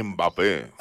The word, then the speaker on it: Mbappé: No, Mbappé.